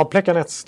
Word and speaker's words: Plekanec: Sen har jag Plekanec i Montreal. – Ja. – Uttalar jag det rätt? – Ja, Plekanec, det ser man faktiskt.